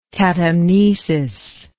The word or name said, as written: catamnesis